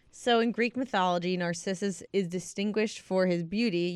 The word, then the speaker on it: Narcissus: Narcissus.